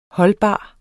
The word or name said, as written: holdbar